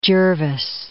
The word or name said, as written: Jervis